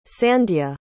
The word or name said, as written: sandya